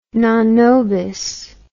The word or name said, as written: non nobis